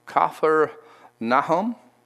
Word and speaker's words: Capernaum: It's pronounced, the word is Kafer Nahum.